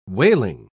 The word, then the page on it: waling: British English